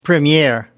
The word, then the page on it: premiere: American English